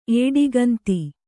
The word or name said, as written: ಏಡಿಗಂತಿ